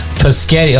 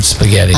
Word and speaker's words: spaghetti: Paschetti. – Oh, there's another one. Yeah. We could do a whole show on, kids mispronounce words like paschetti. How old were you before you could say the word spaghetti? – I don't know.